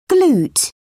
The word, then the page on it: glute: British English